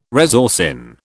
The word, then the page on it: resorcin: British English